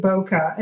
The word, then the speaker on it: Bokeh: It's Bokeh. Is that how you pronounce it? – I pronounce it Bokeh.